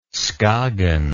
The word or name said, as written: skagen